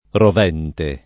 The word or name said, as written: rovente